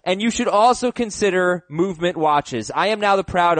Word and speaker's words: MVMT Watches: MVMT Watches, that's pronounced Movement Watches, another awesome sponsor.